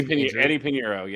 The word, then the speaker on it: Pinheiro: The Panthers kicker, Pinheiro. I'm going to butcher that name. – Yeah, Eddie Pinheiro. Yeah, that's it.